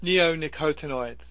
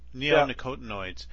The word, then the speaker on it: neonicotinoids: Neonicotinoids. – Neonicotinoids.